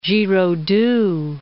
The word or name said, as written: Giraudoux